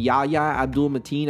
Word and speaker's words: Yahya Abdul-Mateen: But anyways, the Ambulance trailer, Michael Bay movie, it stars Jake Gyllenhaal and Yahya Abdul-Mateen. I believe that's how you pronounce it. – That's exactly how you say it.